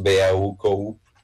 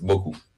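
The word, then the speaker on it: beaucoup: Sí, siempre es un shock para los alumnos decir, por ejemplo, la palabra mucho, que son muchas letras, BAUKU, y nomás BOKU. BOKU, se pronuncia BOKU. ¿Y por qué tanta letra? Porque EAU se pronuncia OU se pronuncia U, la P al final no se pronuncia.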